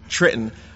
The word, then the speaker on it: Trenton: Well, for most New Jerseyans, when we say Trenton, we're supposed to pronounce it Trenton. – The last T, we don't say the last T for some strange reason, but that's just coming from my side of the fence.